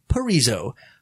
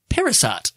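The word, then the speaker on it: Parisot: I'm so glad I looked up how to say his name. And I don't know why I did, because at first when I was going to say it, I'm like, it's directed by Dean Parizot. That's how it looks like it's spelled.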